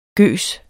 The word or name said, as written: gøs